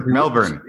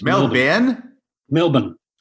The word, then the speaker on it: Melbourne: That's another pronunciation you need to work on. – Melbourne. – Yeah, Australians just say Melbourne. – So it's Melbourne.